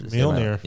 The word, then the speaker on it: Mjolnir: For Mjolnir.